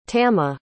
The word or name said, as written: tamma